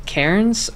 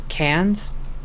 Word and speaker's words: Cairns: I think it's underrated just because I think most people usually base their diving out of Cairns or Cairns, depending on how you want to pronounce it. I have been listening to the British and American pronunciation in my headphones during this recording to make sure I say it right. – Cans, sure, like beer cans.